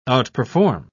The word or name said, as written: outperform